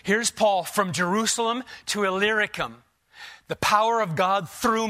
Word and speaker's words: Illyricum: So that from Jerusalem and all the way around to, if you want to know how to pronounce that word, just look for the little word Lyric inside Illyricum. Makes it a lot easier. I failed a lot of times trying to pronounce that word until you see that little word Lyric in there, then you're set.